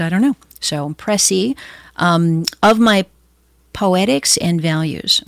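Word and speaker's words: précis: P-R-E-C-I-S, with an accent on the it looks like precis, but it's preci.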